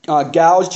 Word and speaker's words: Gouge: And though human language can scarcely communicate this glorious truth, I believe that William, I believe it's pronounced Gouge, G-O-U-G-E, he's one of the ministers of the Westminster Assembly, he said it well when he wrote this.